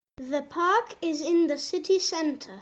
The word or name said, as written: the park